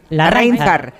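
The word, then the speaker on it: Larráinzar: ¿Lo digo bien? ¿Larrainzar? – Larrainzar.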